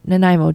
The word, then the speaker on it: Nanaimo: Yeah, Nanaimo, just like the dessert.